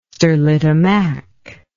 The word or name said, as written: sterlitamak